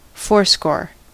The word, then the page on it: quatre-vingts: France (Paris); /ka.tʁə.vɛ̃/